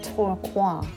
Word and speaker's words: Tourcoing: And today I'm in a new town called Tourcoing, and I discovered Google Pronounce.